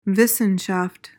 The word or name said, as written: wissenschaft